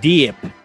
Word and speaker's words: Dyip: The Colombian Diip. That's spelled DYIP.